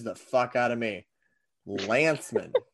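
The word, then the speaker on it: Lancman: Well, so here's the thing. It's L-A-N-C-M-A-N. Yep. Yep. And I have edited I have edited an absurd number of his blogs, and I have called him Lankman to his face via the group chat, and nobody's ever questioned me on it until today.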